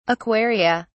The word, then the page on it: aquaria: British English